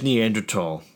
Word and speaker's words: Neanderthal: Well, you know there's Neanderthal.